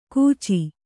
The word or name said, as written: ಕೂಚಿ